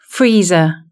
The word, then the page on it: freezer: British English